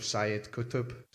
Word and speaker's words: Sayyid Qutb: Sayyid Qutb.